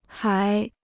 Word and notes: 海: hǎi

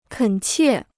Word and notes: 恳切: kěn qiè

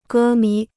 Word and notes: 歌迷: gē mí

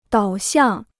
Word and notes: 导向: dǎo xiàng

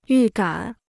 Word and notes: 预感: yù gǎn

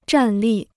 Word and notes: 站立: zhàn lì

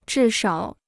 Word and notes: 至少: zhì shǎo

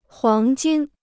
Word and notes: 黄金: huáng jīn

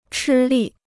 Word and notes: 吃力: chī lì

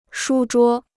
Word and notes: 书桌: shū zhuō

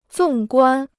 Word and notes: 纵观: zòng guān

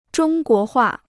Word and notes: 中国画: zhōng guó huà

